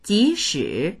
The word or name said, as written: ji2shi3